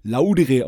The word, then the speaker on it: Kaiserslautern: Kannst du den Akzent schon so ein bisschen, weil wir haben da auch öfters gespielt und wir haben zumindest gelernt, dass Kaiserslautern landestypisch Laudere ausgesprochen wird.